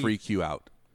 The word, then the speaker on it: Freqout: The Freak You Out. Freak You Out. – But it's spelled like Freqout. – Yeah, like Freak You Out. Free Q Out.